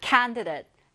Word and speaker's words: candidate: 다음 단어, 자, candidate. candidate or candidate 둘다 가능해요. 이 DAT를 the이라고 발음하셔도 괜찮고요. 여기 지금 우리 교재에 나온 것처럼 date라고 발음하셔도 괜찮습니다.